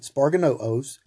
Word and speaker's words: sparganoo: Now, this comes from the Greek word sparganoo, if I'm saying that right. Sparganoo, S-P-A-R-G-A-N-O-O. Sparganoo, sparganoo, which meant to strap or wrap with strips.